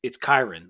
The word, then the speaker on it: Kyren: And Andy Bitter went on TSL later in the day on and said, like, it's Kyren, like Byron. Kyren.